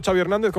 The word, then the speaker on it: Xavi Hernández: What is the correct pronunciation of your first and last name? – Xavi Hernández. – Xavi.